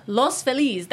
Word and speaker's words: Los Feliz: It's Los Feliz. That's how you say it.